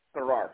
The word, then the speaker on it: Karrar: Karrar, K-A-R-R-A-R. Karrar.